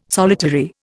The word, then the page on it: solitary: Indian